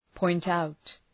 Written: point out

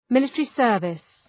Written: military service

